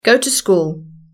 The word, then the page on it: go to school: British English